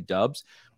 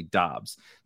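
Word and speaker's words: Doubs: In college, they called him Dubs. And that's why, so if you listen to a lot of like content providers, they'll probably say Dubs because his like college announcers and stuff called him Dubs, and he was like too nice to tell them that it's actually Dobbs.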